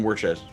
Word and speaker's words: Worcester: It's written Worcester, but it's Worcester. – Worcester, yeah.